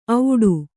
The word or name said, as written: ಔಡು